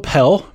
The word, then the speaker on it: Pelle: Are we going Pelle or Pelle on this? – I think it's Pelle, right? – We should know this. We can look at it. Wait, it's got the pronunciation. – I mean, it on. – Took us a while to figure out Rodion's Karutz. – It says Pell. We got Pell in the basketball reference.